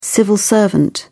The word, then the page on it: civil servant: British English